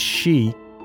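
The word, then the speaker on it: shi (四): The reason being that its character is sometimes pronounced as shi, which is the word for death.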